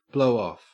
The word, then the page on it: blowoff: Australian English